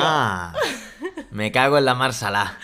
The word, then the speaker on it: me cago en: bueno, ya tenéis en YouTube un vídeo sobre me cago en. Me cago en. La, la pronunciación más propia es me cago, cu, guen, me cago en.